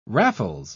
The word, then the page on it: raffles: British English